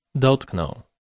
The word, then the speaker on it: dotknął: In case of the vowel ą, if it appears in front of w, It's pronounced as o. For instance, dotknął, he touched.